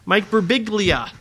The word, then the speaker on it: Birbiglia: Mike Birbiglia.